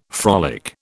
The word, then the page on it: frolic: British English